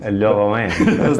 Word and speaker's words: LoRaWAN: By the way, you can say it better if you say it with a French accent. – LoRaWAN. – There we go. – So, what is LoRaWAN?